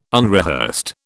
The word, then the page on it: unrehearsed: British English